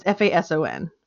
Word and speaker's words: Fason: I'm going to say Fa- it's F A S O N. Fason. Fason? Fason. Hmm. – Fason. – Okay. Fason. I'm going to go with Fason because it's spelled like Jason.